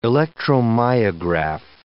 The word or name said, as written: electromyograph